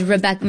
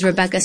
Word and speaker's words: Rebecca: My name is Rebecca. My birth name is Rebecca, spelled with two B's.